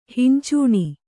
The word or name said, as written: ಹಿಂಚೂಣಿ